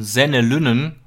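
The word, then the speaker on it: Senne Lynen: Allein die Tatsache, dass zusätzlich zu den Offensivproblemen noch so ein Senne Lünnen, so spricht man ihn glaube ich aus, Innenverteidiger spielen musste, zeigt das Problem eigentlich sehr, sehr gut.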